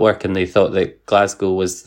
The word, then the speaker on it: Glasgow: How did you say it? – Glasgow. – Yeah, okay, no, you say it right.